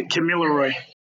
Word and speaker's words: Kamilaroi: I know you're a member of the Kamilaro Nation. I'm sure I pronounced that wrong. – Yeah, Kamilaroi.